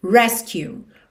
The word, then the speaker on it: rescue: Aqui, muita atenção, porque essa palavra resgate em inglês é rescue. Rescue, ou seja, sílaba tônica é a primeira sílaba.